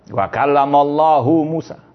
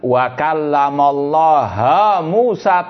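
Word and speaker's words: Wa kallamallahu Musa (وكلم الله موسى): Mereka membacanya dengan wa kallamallaha Musa taklima. Nabi Musa yang berbicara dengan Allah. Itu salah satu bentuk tahrif harokat. Harokatnya dirubah. Wa kallamallahu menjadi wa kallamallaha.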